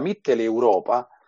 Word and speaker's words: Mitteleuropa: Tra l'altro, la mitteleuropa, varrebbe la pena ricordarlo, non esiste perché non si pronuncia così. È Mitteleuropa, perché l'espressione tedesca, Mittel vuol dire di mezzo in tedesco, e Europa in tedesco si dice Europa con questa R,